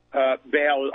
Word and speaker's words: Baal: The Phoenicians were first of all part of the Nephilim tribes, and you're right, it is Baal, I think, is more the correct pronunciation, but I've heard it both ways.